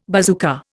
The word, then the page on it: bazooka: Indian